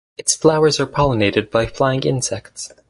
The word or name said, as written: pollinated